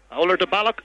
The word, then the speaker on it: Oulart-the-Ballagh: Oulart the Ballagh is the proper pronunciation, but many people call it the Ballagh.